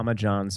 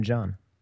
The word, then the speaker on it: John: Yeah, I'm at Arnold, John. Spell it all out, and no H in John.